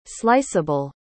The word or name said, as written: sliceable